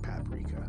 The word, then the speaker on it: paprika: Now, some people don't pronounce paprika like paprika, but we do here at West Coast Cookbook and Speakeasy. In fact, the Netroots Radio Style Manual stipulates that paprika is pronounced paprika. Okay, we'll attribute it to local dialect.